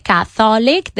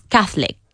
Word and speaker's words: Catholic: No decimos tres sílabas, sino dos. Nada de Catholic. Decimos Catholic. Catholic.